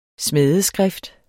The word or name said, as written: smædeskrift